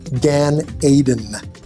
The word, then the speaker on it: Gan Eden: Gan Eden is the correct way to say it.